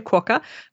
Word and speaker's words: quokka: Now, I've heard of something called I'm probably going to mispronounce it, like a quokka. – Haha, yes. No, you're pronouncing it correctly, a quokka.